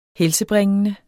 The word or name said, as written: helsebringende